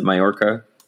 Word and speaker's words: Mallorca: Is that how you pronounce it? Mallorca? – Yep.